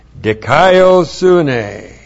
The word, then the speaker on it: dikaiosune: And then we have dikaiosune. D-I-K-A-I-O-S-U-N, long E.